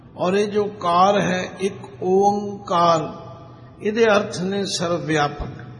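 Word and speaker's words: एक ओंकार (ਇੱਕ ਓਅੰਕਾਰ): कुछ लोग अनजाण पुणे विच इस नू एकमकार भी पढ़ते ने, कोई एकमकार भी पढ़ते ने। इसलिए इसका उच्चारण सानू सारिया नू चंगी तरह समझ के दृढ़ कर लेना चाहिए। पहले बोलो एक, फिर ओंकार, इसका शुद्ध उच्चारण है।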